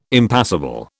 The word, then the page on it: impassible: British English